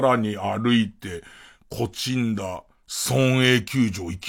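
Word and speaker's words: 東風平: 東に風に平って書いて、こちんだって読むんですけど、そのこちんだ村営球場ってところで練習をしてますよ、つって。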